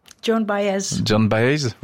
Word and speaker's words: Joan Baez: C'est Joan Baez. – John Baez, vous prononcez mieux que moi.